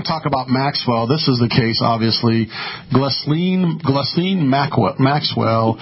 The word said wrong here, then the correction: Ghislaine: I think it's Ghislaine.